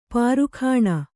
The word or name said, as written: ಪೀರಖಾನಿ